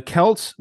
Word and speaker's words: Celts: I gotta interrupt and say thank you for pronouncing Celts correctly. – Yeah, what do people say? Celts? – Celts. Because of the Celtics, but thank you.